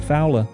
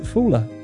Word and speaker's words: Fula: F-O-U-L-A. This coming from a correspondent who was talking about what I thought was called Fowler. And yet someone writes in, good evening, Emil. Regarding Fula in Shetland, this person says, brackets, the language of my family, close brackets, we pronounce it Fula.